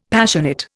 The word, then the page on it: Passionate: Indian